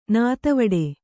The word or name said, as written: ನೀತಿವಿಡಿ